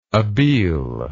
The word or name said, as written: abele